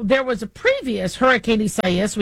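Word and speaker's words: Isaías: So, you know, the media is reminding us that we are pronouncing it Isaias, so, because that is the Latino term for Isaiah. And so even though Isaias is where how many of us Americans would call it, the Spanish pronunciation is what they are using.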